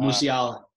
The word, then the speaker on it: Musiala: Um, Musiala, I don't know how to pronounce that, but uh, Musiala.